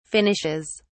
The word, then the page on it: finishes: British English